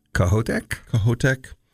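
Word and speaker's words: Kohoutek: Kohotek.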